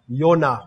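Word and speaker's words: Yonah (יונה): And in Hebrew it is pronounced Yonah. Y-O-N-A-H.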